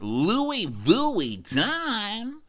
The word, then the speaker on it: Louis Vuitton: Also, why does Andy pronounce it Louis Vuitton? Is that a cute way, or is that how rich people say it? – That's how you say it.